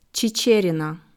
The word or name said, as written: Chicherina